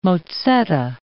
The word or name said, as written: mozzetta